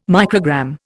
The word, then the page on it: microgram: Indian